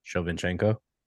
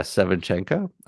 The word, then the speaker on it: Shevchenko: How do you say her name? – Shevchenko. – Yeah, Shevchenko.